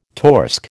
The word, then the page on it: torsk: American English